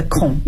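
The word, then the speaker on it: 孔: I'm a Cantonese, so usually when people look at my last name, they will pronounce it just like the Mandarin's Hong. But actually, it's my Confucius, the 73rd generation. So it's a Kong in Mandarin's pinyin.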